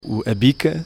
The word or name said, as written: bica